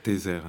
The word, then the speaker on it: Tezer: Tezer, hein, ça s'écrit. Tezer. Je le dis parce que voilà. Quand on prononce le nom, on ne distingue pas forcément. Tezer, T-E-Z-E-R, Özlü, O, tréma, Z, L, U, tréma.